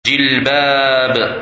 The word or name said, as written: Jilbab